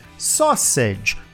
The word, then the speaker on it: sausage: Sausage.